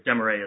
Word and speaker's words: De Morais: That's one way to pronounce it, Your Honor, if you have a French background. – My contact professor is telling me you pronounce it. – We say De Morais, but De Morais is actually correct, okay. So you are right.